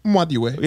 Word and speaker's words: Nwadiwe: Because truly it's Nwadiwe. – Yeah. – So it's like you pronounce, it's the N and W at the same time. Yeah. Nwadiwe.